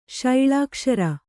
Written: ಶೈಳಾಕ್ಷರ

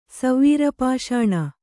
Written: ಸವೀರಪಾಷಾಣ

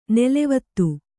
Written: ನಲವತ್ತು

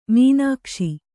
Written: ಮೀನಾಕ್ಷಿ

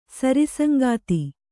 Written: ಸರಿಸಂಗಾತಿ

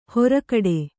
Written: ಹೊರಕಡೆ

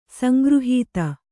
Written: ಸಂಗೃಹೀತ

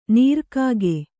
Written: ನೀರ್ಕಾಗೆ

